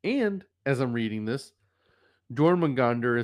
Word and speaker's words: Jormungandr: Jormungandr in Norse mythology.